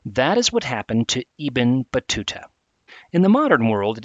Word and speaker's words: Ibn Battuta: That person's name is spelled I-B-N space B-A-T-T-U-T-A. Ibn Battuta. His book is called The Rilla, R-I-H.